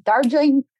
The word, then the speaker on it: Darjeeling: Darjeeling, yeah.